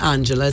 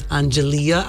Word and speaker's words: Angelia: It's not Angela, it's Angelia, as in Richard Marx.